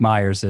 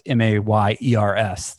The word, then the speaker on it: Mayers: Mike Myers is. It's Mayers. That's why we were debating the pronunciation.